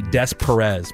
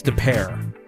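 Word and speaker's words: De Pere: Like I know like when you go to St. Louis, it looks like it'd be Des Perez, but it's DePere.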